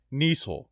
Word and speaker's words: Niesel: I'm thankful for Kenneth Niesel. His last name rhymes with Diesel, and I'm thankful for him.